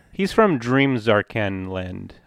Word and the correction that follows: Zanarkand: I, am going to say it wrong each time. So there's a real Zarkan Land way back in the day, and Zanarkand. Zanarkand. Jeez.